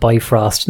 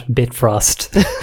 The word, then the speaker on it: Bifrost: Well, my discovery is a very simple one, really, but I discovered that the Bifrost is actually pronounced and spelled Bifrost, not Bitfrost.